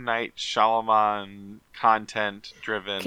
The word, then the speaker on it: Shyamalan: Shyamalan, yeah. M Night Shyamalan. – Shyamalan.